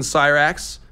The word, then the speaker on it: Syrax: the dragon Syrax, as it's pronounced.